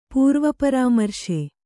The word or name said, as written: ಪೂರ್ವಪರಾಮರ್ಶೆ